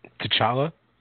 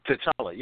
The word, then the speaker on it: T'Challa: T'Challa? – T'Challa, yeah.